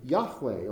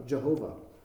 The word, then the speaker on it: Yahweh: The name Yahweh, or sometimes it's been mispronounced Jehovah, is a spinoff.